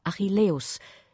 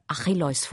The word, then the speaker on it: Achilleus: Er wählt hier übrigens die griechische Aussprache Achilleus, während wir im Folgenden der gebräuchlicheren lateinischen Aussprache Achilleus folgen.